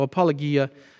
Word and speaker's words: apologia: It's a hard G in Greek, but in English we say ja. So apologia, it's used all over the place in the ancient world and later in Christian literature and in the New Testament, of course.